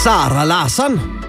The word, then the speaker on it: Sara Larsson: Sara Larsson. – Ja, det skal udtales på svensk. Ikke sikker på, det var så svensk, men det lød lidt derhenad.